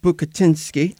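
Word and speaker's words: Bucatinsky: Because you're partners with Dan Bukatinski.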